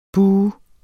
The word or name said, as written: buh